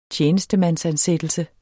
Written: tjenestemandsansættelse

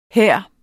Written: her

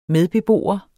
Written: medbeboer

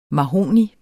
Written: MAHOGNI

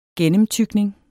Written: gennemtygning

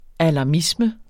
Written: alarmisme

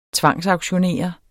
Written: tvangsauktionere

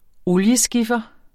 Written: olieskiffer

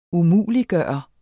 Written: umuliggøre

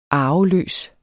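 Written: ARVELØS

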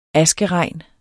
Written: askeregn

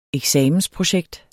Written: eksamensprojekt

